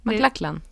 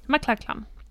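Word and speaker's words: MacLachlan: Nej, men jag kan inte uttala det namnet. Men McLachlan. – McLachlan.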